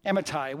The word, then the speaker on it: Amittai: Jonah, son of Amittai. Well done. You pronounced that well.